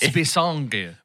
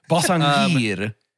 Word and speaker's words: Basinger: Is it Basinger? – Yeah. – I said Basinger like an idiot. – No, I know I'm the last one to trust on pronunciation. – I don't know. – It's Basinger. – Basinger.